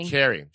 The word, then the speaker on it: Sha'Carri: Sha'Carri.